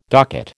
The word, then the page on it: docket: American English